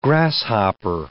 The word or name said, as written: grasshopper